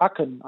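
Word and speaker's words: Akın: Akin, das I bei dem Namen Akin ist ohne I-Punkt, das heißt, man spricht es wie ein E bei Katze aus, ja, Akin eigentlich, sagt aber keiner. Ich glaube, Fatih Akin äh,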